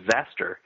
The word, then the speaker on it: Xastir: After that, there's an article about a piece of software called Zastir. I'm assuming that's the way you pronounce it, X-A-S-T-I-R, which is a client for the Automatic Packet Reporting System, or APRS.